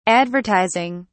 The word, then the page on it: advertizing: British English